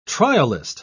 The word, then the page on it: trialist: British English